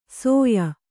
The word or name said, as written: ಸೊಯ